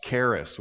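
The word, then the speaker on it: charis (χάρις): The word grace, our English word grace in the Bible, comes from a Greek word pronounced charis, which means love, favor, kindness, blessing.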